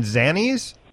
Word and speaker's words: Zanies: It's a hard A. It's Zanies. – Okay. I should learn that by Saturday. Thank what were you saying? Zanies? – I was saying Zanies.